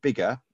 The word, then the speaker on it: Biggar: So I suppose to think of some that are doing well, and one that I always like is a place called Bigger in Lanarkshire in Scotland. So so it's spelt B-I-G-G-A-R, and it's a little town, about 4,000 or 5,000 people, I think, and it's nearly all independents.